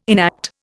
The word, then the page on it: enact: Indian